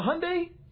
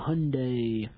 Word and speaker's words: Hyundai: How do you say the other car company's name? – Hyundai. – Hyundai. – That's Hyundai. – Well, they changed the name so it was more like Honda. – Hyundai. – So that they would sell more, but you really pronounce it Hyundai.